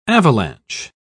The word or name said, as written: avalanche